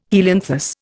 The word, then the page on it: helianthus: Indian